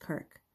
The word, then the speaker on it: Circe: Her name is sometimes pronounced Kirk, but the origins and meaning of her name are less clear.